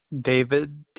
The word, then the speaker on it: David: David, D-A-V-I-D. – S-P-A-R-K? – S-P-A-R-K-S.